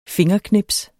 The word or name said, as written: fingerknips